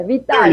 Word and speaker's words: Vitale: Vitale.